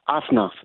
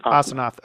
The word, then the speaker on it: Asenath: Asenath. – Mm-hmm. Asenath, okay.